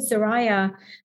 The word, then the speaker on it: Soraya: And that started with Queen Soraya, that's how you say her name, with an S, in the 1920s.